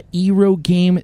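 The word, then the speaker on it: eroge: and he is a Ero game developer. – Yes. Well, technically, the pronunciation is Ero game. – Ero game. Look at that. – Like Arrow.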